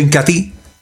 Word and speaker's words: Incatea: Se escribe Incatea, pero se pronuncia Incatí.